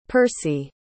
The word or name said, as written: Percy